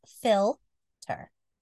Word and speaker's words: philter: It's spelled P-H-I-L-T-E-R. And pronounced filter.